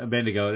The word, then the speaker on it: Abednego: Shadrach, Meshach, and Shadrach, Meshach, and Abednego. I can't ever pronounce it. Abednego. There we go.